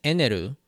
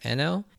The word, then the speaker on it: Enel: So, in the Crunchyroll translations, as well as a few other places that I've seen, translate his name as Eneru. While this isn't technically incorrect, as that is the romanization and pronunciation of the Japanese name, but in this case, it should be pronounced Enel. And the reason for this is that, so in Japanese, there's no L sound, really. So it's often done using the R sound,